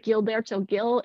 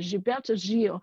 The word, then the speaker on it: Gilberto Gil: It sounds like Gilberto Gil in English, but in Brazil they pronounce it Gilberto Gil.